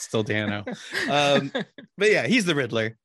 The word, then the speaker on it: Dano: Paul Dano, which I found out is how you really pronounce the last name, go figure, as the Riddler.